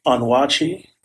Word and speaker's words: Onwuachi: So my name is James Onwuachi, and the last name is spelled O N W U A C H I, and it's a highly unusual last name.